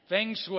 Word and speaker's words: feng shui: How do you say it? Feng shui. Feng shui. Thank you.